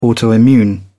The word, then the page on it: autoimmune: British English